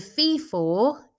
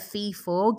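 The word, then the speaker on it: fith-fath: A fee-for, and if you're looking it up, it's spelt F-I-T-H space F-A-T-H. So a fee-for gives you fee-fi-fo-fum vibes, is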